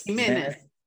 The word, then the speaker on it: Ximenes: Jimenez, that, that, that X for everyone who want to know about Spanish linguistics. – Yes. – So that's that sound um, is pronounced, it's like an aspiration, it's like ha. Uh. – Ah. – So it's like a J.